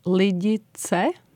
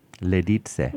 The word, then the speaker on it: Lidice: So we pronounce it as Lidice. – Lidice. – Lidice, because there is the ts sound.